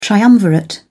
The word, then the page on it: triumvirate: British English